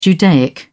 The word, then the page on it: judaic: British English